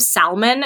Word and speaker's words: salmon: Like, there's things that he says sometimes, like he pronounces the L in salmon sometimes. He's like, yeah, so you take this piece of salmon, and I'm like, oh my God, dude, what are you doing?